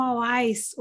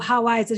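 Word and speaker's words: Houaiss: Outra coisa que eu me recordo muito bem é como era caro comprar um Hawais ou Hawais. A gente viu que as duas pronúncias, elas são usuais do português brasileiro.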